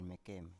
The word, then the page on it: mekem: Biak